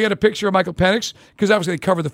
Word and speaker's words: Penix: It's Penix.